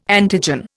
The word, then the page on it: antigen: Indian